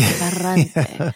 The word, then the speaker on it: Ferrante: So what is it about the Ferrante book that seems, I'm pronouncing it in my Italian way, the Ferrante, that seems outside of your reading universe? – I don't read novels of story very often.